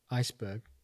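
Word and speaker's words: Esbjerg: And football wise, what was it like going from a club like Iceberg, if I pronounced that correctly? – Yeah, that's fine.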